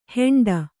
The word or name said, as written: ಹೆಂಡ